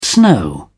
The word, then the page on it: snow: English; British English